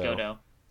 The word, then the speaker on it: Godot: Godot. You don't pronounce the T.